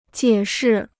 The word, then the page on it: 解释: jiě shì